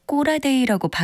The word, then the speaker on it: 고라데이: 고라데이라고 쓰지만 경수는 꼬라데이라고 발음해야 진짜 꼬라데이 같았다.